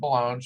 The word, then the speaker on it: Ballone: Is it Ballone? – It's Balloon like cologne with a B. – Okay, this is Dr. Pat Ballone.